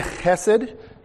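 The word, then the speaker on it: chesed (חסד): And the word chesed is spelled with a he, but it is that kind of guttural. And sometimes you'll see it spelled C-H-E-S-E-D instead of H-E-S-E-D, because they're trying to get across that sound.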